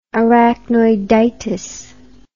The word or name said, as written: arachnoiditis